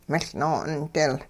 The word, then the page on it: miL-noo'ondil: hupa